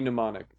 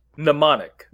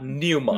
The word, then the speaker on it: mnemonic: It's spelled like Johnny Mnemonic. – So how is it pronounced? – Mnemonic. – Mnemonic. – Yeah. But not pneumonic.